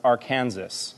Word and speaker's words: Arkansas: A young man in Arkansas, although I think it's actually pronounced Arkansas, I'm pretty sure.